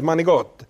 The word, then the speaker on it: manicotti: Like I would say, manicotti. It's not manicotti, it's manigot.